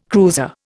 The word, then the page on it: cruiser: Indian